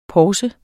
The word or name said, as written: PORSER